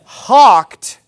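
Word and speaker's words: houghed: And David hawked, that's how that word's actually pronounced, believe it or not. English has some pretty weird spellings.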